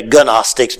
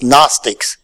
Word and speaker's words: Gnostics: It's spelled with a G, but the G is silent. Some people say Gnostics, but that's not a correct statement. It's Gnostics.